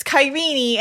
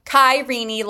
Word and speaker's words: Kyrene: It's Kyrene. And I'm like, Kyrene, like Mr. Feeny from Boy Meets World?